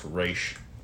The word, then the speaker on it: Ra's al Ghul: A lot of the pronunciations I've heard, and I think it's the most accurate one, it's actually supposed to be pronounced Ra's.